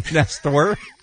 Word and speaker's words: Nestor: How do you say your name, Nestor? – I say Nestor.